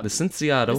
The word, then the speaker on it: Licenciado: Because I don't think that would be a hard C, like Cuba. – Licenciado.